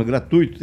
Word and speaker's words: gratuito: E dizer o seguinte: para paroxítono gratuito tem acento tônico no TUI na sílaba tônica tui.